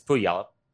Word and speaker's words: Puyallup: Do you know how it's supposed to pronounce? – It's Puyallup. – Okay. – Yeah, just a little on the. – But everybody says Puyallup, even though it looks nothing like that.